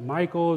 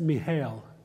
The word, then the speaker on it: Michal: Is it Michael? Is it, it's Michal. That's how the Hebrews would have pronounced it. The C would have been silent.